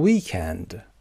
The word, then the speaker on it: weekend: یادتون باشه امریکن قسمت اول استرس میگیره، اما اگر بریتیش صحبت میکنید weekend استرس قسمت دوم.